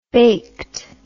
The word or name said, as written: baked